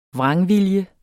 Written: vrangvilje

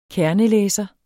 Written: kernelæser

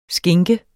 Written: skinke

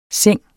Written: seng

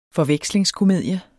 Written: forvekslingskomedie

